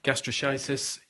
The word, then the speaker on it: gastroschisis: Um, let's have a little look at gastroschisis. Is that how you pronounce that? – That's right, gastroschisis.